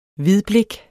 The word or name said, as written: hvidblik